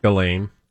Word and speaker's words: Ghislaine: And this human's name is, you say it, because I think you learned a different way to say her name. – Ghislaine. – Oh, okay. – Ghislaine Maxwell.